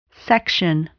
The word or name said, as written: section